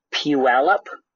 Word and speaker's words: Puyallup: It's called Puyallup.